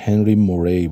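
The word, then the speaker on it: Henry Murray: مثل تد گفتیم، TAT، که آقای هنری موری فکر میکنم تلفظ درستش هنری موری باید باشه، چون فارسی موری نوشته میشه، هنری موری و کریستینا مورگان همسرش بوده.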